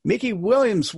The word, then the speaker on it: Mikki Williams: She is passionate about sharing her immense wealth of knowledge on the art, heart, and I almost said that right, on the art, heart, and business of both speaking and storytelling. Mikki Williams, welcome to the show.